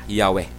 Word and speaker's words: Yahweh: ang YHWH i-pronounce gidna nga Yahweh.